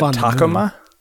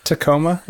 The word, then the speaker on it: Tacoma: Are you guys really pronouncing it Takoma? – Yeah. That's like a taco's mother. – Isn't it just Tacoma? Like, isn't it just Space Station Tacoma or whatever? – Pshaw.